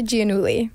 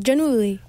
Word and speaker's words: Giannulli: It's Olivia Giannulli. – Oh, Giannulli. – Yeah, it's Italian. – Ooh. – But I think I even pronounce it wrong. I think it's supposed to be like Giannulli.